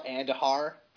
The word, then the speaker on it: Andujar: How do you even say his name? Andujar. – It's Miguel Andujar.